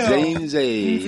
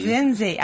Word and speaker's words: Nzenze: Nzenze. – Nzenze.